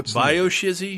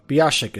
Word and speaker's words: BioShock: Bio-shizzy, if I'm English? – Yeah, Bio-shizzy. – Bioshock. – Bioshock is, I believe, how it's Bioshock.